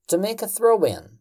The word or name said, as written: to make a throw-in